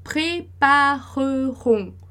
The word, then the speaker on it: prépareront: Então, a gente tem o E como café, pré-pa-re, sem acento, E sem acento é mudo. Re-ron. Então, pré-pa-re-ron.